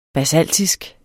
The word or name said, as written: basaltisk